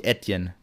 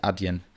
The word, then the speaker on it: Adyen: die Firma ist, glaube ich, Etienne, nicht Adyen.